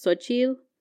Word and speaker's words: Xochitl: Yeah, because it's technically pronounced Xochitl. – Oh, but like nobody could pronounce the L.